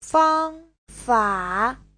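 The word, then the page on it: fangfa: fang1fa3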